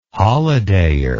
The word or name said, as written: holidayer